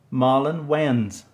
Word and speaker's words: Marlon Wayans: Marlon Wayans.